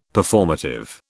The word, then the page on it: Performative: British English